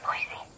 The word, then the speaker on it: Boise: You know it's actually pronounced Boise? – Is it really? – Yeah. My boss is from there. And I've said it wrong a few times. And so I get corrected.